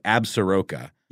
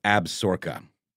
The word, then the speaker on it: Absaroka: But on paper, it looks like Absaroka. – Yep. – But I believe it's Absorka. – Absorka. – Absorka. So, like, you know, if you're looking at a pronunciation guide, it's like ab lowercase dash capital sork. You know, for the emphasis.